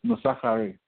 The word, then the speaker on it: Nosakhare: The K at the middle is pronounced in silent, so it's Nosakhare.